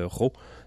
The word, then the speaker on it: Rho: Le duo s'appelle Ro. Ça s'écrit R-H-O.